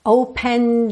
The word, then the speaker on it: opened: Opened me. Atenção que o E final do opened não é pronunciado.